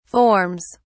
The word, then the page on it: forms: British English